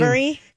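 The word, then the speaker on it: Edebiri: Ayo Adebiri is how you say her Adebiri. – Name. Adebiri.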